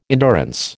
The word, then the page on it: endurance: American English